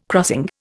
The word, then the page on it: crossing: Indian